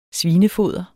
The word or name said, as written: svinefoder